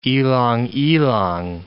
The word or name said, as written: ylang-ylang